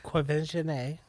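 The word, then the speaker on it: Quvenzhané: Quvenzhanay.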